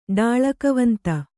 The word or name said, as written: ಡಾಳಕವಂತ